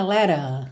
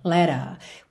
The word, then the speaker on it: letter: Então, no caso aqui, a palavra letter fica letter, letter.